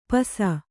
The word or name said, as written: ಪಿಸಿ